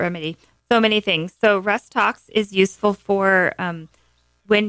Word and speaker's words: Rhus Tox: So Rhus Tox, folks, is spelled R H U S. Tox T O X, which stands for toxicodendron.